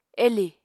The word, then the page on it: elì: Apatani